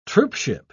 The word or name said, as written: troopship